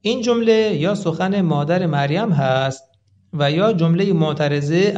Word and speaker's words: مریم: تلفظ مریم در زبان عبرانی با کسره میم هست، مریم گفته میشه، و این اسم قبلا میان بنی اسرائیل وجود داشته.